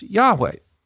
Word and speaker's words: Yahweh: It's a shortened form of the four-letter Hebrew name for the Lord, Yahweh, Yud-Heh-Wow-Heh, which is usually pronounced Yahweh.